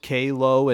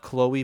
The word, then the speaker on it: K-Lo: Pronounce it K-Lo and not Chloe, please.